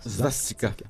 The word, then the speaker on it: Svastika: Donc, la Svastika, c'est comme ça qu'on prononce ça? – Svastika, oui, oui, oui, oui.